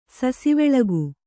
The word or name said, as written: ಸಸಿವೆಳಗು